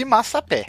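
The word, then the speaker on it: massapê: Eu, qual é a pronúncia? Porque se for em português é pé, se for em francês é P. E aí? Eu aprendi Massapé. Eu aprendi Massapé. Ah, então é Massapé, então.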